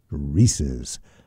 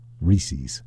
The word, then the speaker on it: Reese's: But according to what I looked up here, the official Google AI says the official and correct pronunciation of Reese's peanut butter cups is Reese's, rhyming with pieces or nieces, named after its creator, H.B.